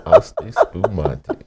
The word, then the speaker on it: Asti Spumante: It had to be some Asti Pomonti or something. – Asti Spumante. – And she didn't even say it right.